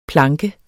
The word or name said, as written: planke